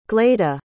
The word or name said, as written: gleda